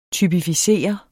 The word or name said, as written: typificere